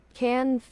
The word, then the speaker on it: Cannes: It's not Cannes Film Festival. – Cannes Film Festival. – Okay. So I'm just going to say we went to the Cannes Film Festival. Okay. – Cannes Film Festival. Cannes Film Festival. – I'm just going to say, yeah. Okay. Silent S. Whatever.